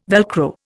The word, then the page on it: Velcro: Indian